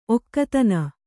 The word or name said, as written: ಒಗತನ